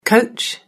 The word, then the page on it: coach: British English